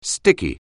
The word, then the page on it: sticky: anglais